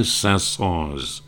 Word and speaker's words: Saint-Saëns: Well, I learned years ago that in that particular case, if you speak French, you have the option of saying, if it's your proper name, of pronouncing the S or not pronouncing the S. And the Saint-Saëns family apparently said that they wanted to, not apparently, they did say they wanted it pronounced. So it's Camille Saint-Saëns and Onfale's Spinning Wheel.